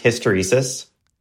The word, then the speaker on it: hysteresis: It's pronounced hysteresis.